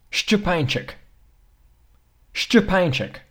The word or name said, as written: szczepanczyk